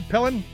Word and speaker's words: Pillen: So, Nebraska, the governor of Nebraska, a guy named Jim Pillen, I think it's how you pronounce it, Pillen, P-I-L-L-E-N, evidently signed